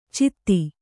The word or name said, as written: ಛದಿ